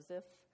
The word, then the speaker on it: Ziph: So Saul has convinced the people of Ziph, that's how you pronounce it, that David and his men are treasonous outlaws.